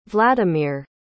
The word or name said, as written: vladimir